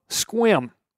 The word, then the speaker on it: Sequim: All you darned Washingtonians, from Spokane to Hump Tulips, I pronounce the town of Squim. Like, I'm sorry, I am wet. I just got done with a short squim, spelled S-E-Q-U-I-M.